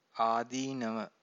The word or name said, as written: adeenava